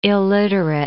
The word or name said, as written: illiterate